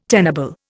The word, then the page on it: tenable: Indian